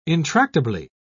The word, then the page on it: intractably: British English